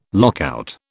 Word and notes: lockout: British English

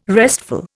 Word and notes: restful: Indian

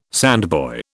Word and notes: sandboy: British English

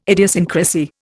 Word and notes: idiosyncrasy: Indian

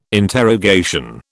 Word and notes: interrogation: British English

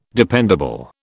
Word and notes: dependable: British English

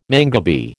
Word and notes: mangabey: American English

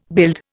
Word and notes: build: Indian

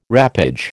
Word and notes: wrappage: American English

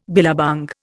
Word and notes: billabong: Indian

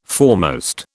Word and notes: foremost: British English

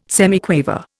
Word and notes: semiquaver: Indian

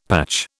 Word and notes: patch: British English

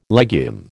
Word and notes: legume: American English

